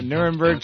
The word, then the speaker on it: Nuremberg: I haven't seen this many American generals come out against someone since the Nuremberg trials. – Yeah. – Did I say that right? – Yeah, Nuremberg trials. You got it.